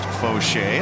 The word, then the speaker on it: Faucher: Calvin Fauché, spelled F-A-U. C-H-E-R, but pronounced Fauché.